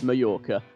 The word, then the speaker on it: Mallorca: Because in Spanish, you pronounce the double L as a Y. Therefore, Mallorca is actually pronounced Mallorca.